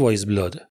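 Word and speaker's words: Weyes Blood: تلفظ درستش ولی همون وایز بلاده.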